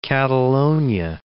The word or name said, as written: Catalonia